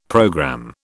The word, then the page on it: programme: British English